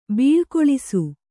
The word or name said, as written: ಬೀಳುಗೊಳಿಸು